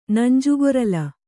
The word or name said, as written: ನಂಜುಗೊರಲ